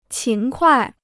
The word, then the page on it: 勤快: qín kuài